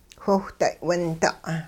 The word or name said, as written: whiwhday' winda'